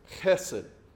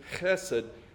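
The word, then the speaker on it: hesed (חסד): Now, the word is actually pronounced hesed, but the fact is there will be a lot of times when I just say hesed because an American accent is not accustomed to using the "h," unless you're dealing with sinus issues, of course. So that is the proper pronunciation, hesed. But you will hear me just say hesed, I'm sure.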